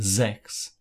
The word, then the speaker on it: sechs: And let's note the pronunciation of sechs. The S sounds like a Z.